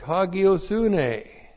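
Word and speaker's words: hagiōsynē: The goal is the accusative singular direct object, hagiosune. Looks like that in the Greek. H-A-G-I long O-S-U-N long E. Hagiosune.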